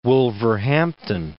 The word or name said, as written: Wolverhampton